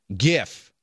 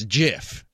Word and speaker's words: GIF: And this from the 630, and it's a hard G GIF. This isn't peanut butter. It's graphical interchange format. I understand that, but the guy who invented it said it's GIF, and he invented it, so he gets to name it.